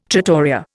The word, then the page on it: trattoria: Indian